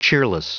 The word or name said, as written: cheerless